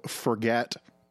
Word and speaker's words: Forget: In Saskatchewan, there's a town literally called Forget. – I don't remember that one. – Yeah, it's apparently pronounced Forge, but it's just spelt Forget.